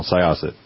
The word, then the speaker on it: Syosset: Well, the problem with this, Marty, and I appreciate your elucidation of these topics, but also the correction on the pronunciation of Syosset. – Oh, sure. – So I've got it now, Syosset.